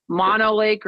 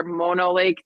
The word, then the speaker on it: Mono Lake: The Great Salt Lake, Mono Lake or Mono Lake, depending on how you say it,